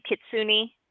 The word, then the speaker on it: Kitsune: Okay, am I saying this correctly? Kitsune? Is that how you say it? – Yeah. – Fox Books. – Yep. – Kitsune? – Yes.